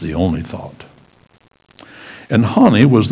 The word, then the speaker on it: Honi: His name was Hanni. That's the way I pronounce it, H-O-N-I.